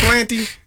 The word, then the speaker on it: Ypsilanti: It's not Ypsilanti.